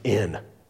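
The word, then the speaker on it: in (ἐν): Incidentally, do you know how to pronounce the Greek preposition for in? Anybody know that? It's in. Ta-da, Greek. Yeah, it's in. It's pronounced the same way.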